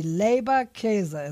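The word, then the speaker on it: Leberkäse: It was like Labor, like Labor Day, and then like K-A-Y-S-E, Labor Käse. It's real easy.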